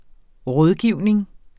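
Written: RÅDGIVNING

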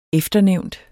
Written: efternævnt

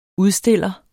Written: udstiller

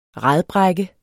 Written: radbrække

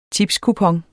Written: tipskupon

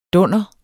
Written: dunder

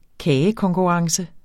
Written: kagekonkurrence

